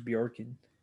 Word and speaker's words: Bjorkman: This year, their new coach, Nate Bjorkman? I believe I'm pronouncing that correctly. – Yeah, Nate Bjorkman.